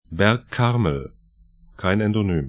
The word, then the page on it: Karmel, Berg: bɛrk 'karml